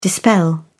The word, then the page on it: dispel: British English